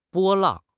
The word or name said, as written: 波浪